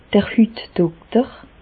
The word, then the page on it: d'r Hütdokter: Bas Rhin; Reichshoffen